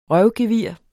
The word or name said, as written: røvgevir